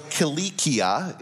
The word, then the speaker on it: Cilicia: "When we had sailed across the open sea along the coast of Sicilia," or Cilicia is how it's pronounced, "and Pamphylia, we came down to Mura in Lucia.